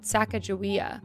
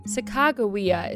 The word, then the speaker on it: Sacagawea: Though sometimes called Sacajawea, Sacagawea is believed to be the correct pronunciation, as there's no soft G in the Hidatsa language.